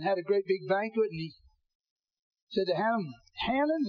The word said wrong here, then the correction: Haman: is that the way you pronounce his name? Y'all, Haman. Oh, you black folk, help me out. I'll say it like the black folks say it. Haman, is that it?